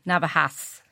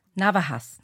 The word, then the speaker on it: Navajas: Okay, I'll spell it N-A-V-A-J-A-S. So people say Navajas. It's okay. – Navajas. Navajas, not Navajas, okay? – Okay.